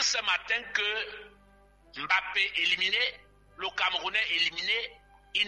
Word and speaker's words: Mbappé: Il a porté le drapeau de ses origines camerounaises, il faut toujours prononcer son nom en Douala, Mbappé.